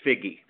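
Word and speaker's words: Figi: Pidgey, or is it Pidgey? Pidgey, did I say that right? – Yes, Charlotte Figgy.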